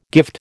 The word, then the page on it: gift: American English